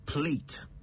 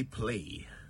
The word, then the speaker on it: plait: I've been meaning to ask you, especially those of you with long hair, is it plait or plait? Well, it's spelled P-L-A-I-T, but it's pronounced plait in British English. The Americans, however, say plait. Some even say play.